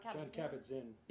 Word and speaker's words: Kabat-Zinn: John Kabat-Zinn.